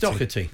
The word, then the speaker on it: Doherty: It's Doherty.